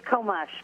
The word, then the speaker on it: Komush: Did I say your last name right? – It's Komush, but it was close enough.